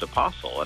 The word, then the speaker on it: apostle: I would pronounce it with a hard T. And several years into doing that, my friend Josh said to me, he said, I always thought apostle was a pronounced apostle. And I was like, no, I think it's got a hard T, like other words, like whistle.